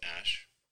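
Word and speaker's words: Æ: And then the AE is, like, pronounced Ash.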